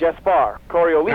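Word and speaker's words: Gaspard Coriolis: Gaspar. Coriolis.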